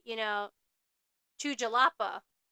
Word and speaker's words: Jalapa: However, us Americans, we're not pronouncing the J properly, so they were going to Jalapa. – So we were saying the J in English. However, this is a capital city in Mexico, so it's pronounced like an H, so Jalapa.